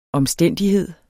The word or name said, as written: omstændighed